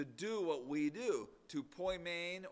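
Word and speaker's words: poimen (ποιμήν): The New Testament was written in the Greek language, and the Greek word for shepherd that we translate shepherd is pronounced poimen.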